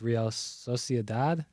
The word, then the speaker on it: Real Sociedad: Real, I'm butchering the name. It's Real Sociedad.